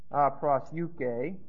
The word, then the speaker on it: proseuche: Here's prosyuke, the normal word for prayer. P-R-O-S-E-U-C-H-E, long E. Supplication is deesis, D-E-S-C-E, long E. D-E-E, long E-S-I-S, and request is a form of aiteo.